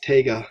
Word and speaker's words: Taiga: No, it's literally T-A-I-G-A. – I'm going to get on my J-Thon. – J-Thon, did you ever get that working? – I think it's pronounced Taiga.